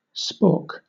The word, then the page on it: spork: Southern England RP; /spɔːk/; American English; /spoəɹk/; /spɔɹk/